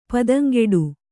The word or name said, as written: ಪದಂಗೆಡು